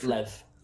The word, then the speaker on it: Lev: Lev.